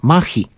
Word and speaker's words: maja: I tu właśnie pojawia się prawdziwe znaczenie słowa Maja, bo kobieta na tym płótnie jest ubrana w typowy strój Mai, a konkretnie machi, bo tak powinniśmy poprawnie wymawiać to słowo i nazwę tych obrazów.